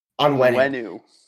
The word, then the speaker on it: Onwenu: Help me pronounce Onwenu.